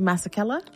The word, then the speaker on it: Masekela: And my favorite was out of South Africa, and please forgive me if I butcher the last name, but Hugh Masekela.